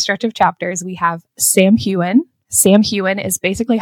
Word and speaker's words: Samhain: So that is pronounced Samhain. We also have some mentions of word marks. And word is spelled W-Y-R-D, but it's pronounced W-O-R-D.